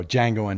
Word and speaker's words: Django: There was a film a few years ago, Django Unchained, mm-hmm. right? By with a D. – Quentin Tarantino, yeah. right? The D is silent.